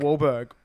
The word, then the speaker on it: Wahlberg: Wahlberg. – They both have silent H's. – Well, I mean, silent H's in their name too.